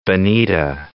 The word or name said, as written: Bonita Springs